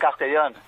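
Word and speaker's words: Castellón: Is it Castellon? Is that how you pronounce it? – I'm from Spain. I'm from Castellon.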